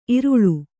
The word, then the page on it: ಈರಳ್ಳೆ: Kannada